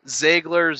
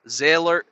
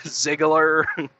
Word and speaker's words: Ziegler: I've heard Ziegler. I think somebody even said like Ziller or something. Like, I guess they thought the G was silent. Ziegler.